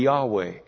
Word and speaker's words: Yahweh: If you pronounced it, it would be Yahweh.